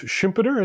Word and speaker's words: Schumpeter: um, i you know it was interesting i i you're the one who introduced me to joseph schumpeter is that how you say his name uh-huh.